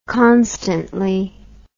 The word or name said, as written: constantly